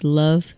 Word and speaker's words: Lauv: His name is Love, L-U-V, L-A-U-V. – L-A-U-V. – Like Louvre, Love, but. – L-A-U-V is how you spell it.